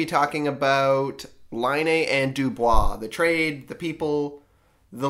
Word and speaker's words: Dubois: So anyhow, the trade we should talk about is Pierre-Luc Dubois.